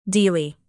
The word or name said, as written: dili